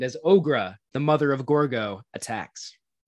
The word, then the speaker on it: Gorgo: I just want to say the emphasis, I believe, is on the first syllable of Gorgo, not Gorgo. – Gorgo would be the French pronunciation. Yes. This is the British pronunciation, Gorgo.